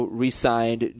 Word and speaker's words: re-signed: Is that how you pronounce that? Resigned, Julian Edelman? – Okay. Well, it depends on how you're using the word. – It's re-signed. – You see, Adam... Yes. It's spelled the same, but there's two different ways.